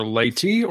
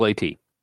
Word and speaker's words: Leyte: Oh, okay, so apparently it is pronounced most commonly either Leyte or Leyte. – Go with Leyte.